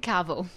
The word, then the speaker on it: Carville: I didn't say your last name right. Say it for me. Carvel.